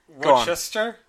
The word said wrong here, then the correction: Worcestershire: You missed off the last five letters. – Well, okay. Oh, Rochester. Rochester. I don't know how to pronounce that. – It's Worcestershire. – Worcestershire? – Worcestershire. Okay.